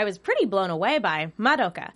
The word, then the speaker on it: Madoka: You said it right, by the way. A lot of people pronounce it Madoka, which is, you know, fair enough. That's what it looks like it should be, but it is Madoka.